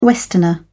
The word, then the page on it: westerner: British English